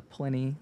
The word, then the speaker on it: Pliny: There's 700 Pliny, known as a 2011. – While I'm correcting your pronunciation, I believe it's a Pliny. It's what people usually say.